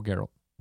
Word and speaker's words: Geralt: Doug Cockle as Geralt. – Oh, Geralt. – Geralt. – No, Witcher. – Witcher. Yeah. – Hard G.